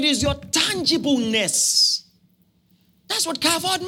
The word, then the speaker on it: kavod: kavod. Kavod is spelled K-A-V-O-D. K-A-V-O-D.